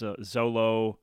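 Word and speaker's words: Xolo: It's X-O-L-O, so I think the X is a Z.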